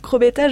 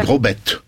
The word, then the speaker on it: Crobette: Ce qu'on entend ici, on vous avait présenté Edmond Villoux comme l'interprète de Jules Crobetta, je crois. – Vaudois, on prononce pas le AZ, on dit Crobette.